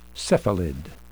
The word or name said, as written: cephalid